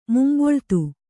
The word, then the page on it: ಮುಂಬೊಳ್ತು: Kannada